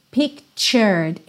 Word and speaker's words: pictured: Pictured. Esse T é ch, ch. Então, pictured. Pictured I was driving home to you.